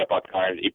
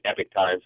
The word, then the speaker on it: Epoch Times: Yeah, I think people pronounce it different ways, but yeah, the Epoch Times, Epic Times, some people call it, but I've been doing a series on education for them.